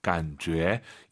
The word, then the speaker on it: 感觉: 간죄. 이 제이유이는요, 주애가 아니에요. 너의 죄입니다. 간죄, 간죄. 이렇게 발음하시기 바랍니다.